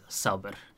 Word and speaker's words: Saber: However, it is a nickname, and it's pronounced as Saber. – Saber, like patience? – Yeah, fair enough.